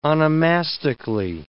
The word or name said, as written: onomastically